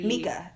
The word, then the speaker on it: Mika: Mika. – Is that how you pronounce it? – It is how you pronounce it.